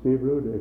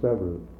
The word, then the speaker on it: Severud: I think you all know my name is Sivrud. It's a good Norwegian name. If you're Norwegian, you pronounce it Sivrud. If you're anything else, you pronounce it Sevrud. I can be spelled S-E-V-E-R-U-D.